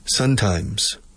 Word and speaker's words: sometimes: And they visualize these words separate in their brain when they're speaking, and they mispronounce, they don't pronounce the M at the end of some. They say sun all the time. Sometimes.